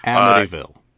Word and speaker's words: Amityville: Jeez, I can't even say it if I say that right. – Amityville. – Thank you.